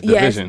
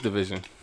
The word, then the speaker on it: DVSN: DVSN. Or I'm bad. Division. Yes, Division. – That's how you pronounce his name? – It's Division. – That's how you pronounce it? Yeah. DSVN? – DVSN. Division. – Oh, that's how he's pronounced.